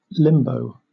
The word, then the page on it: limbo: British English